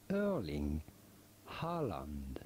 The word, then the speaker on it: Haaland: We are looking at how to pronounce the name of a for German Bundesliga club Erling Haaland.